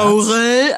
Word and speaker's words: Aurel: Aurel, also wie beschissen kann man denn Aurel aussprechen, bitte? Aurel. – Ach so, sagt man Aurel.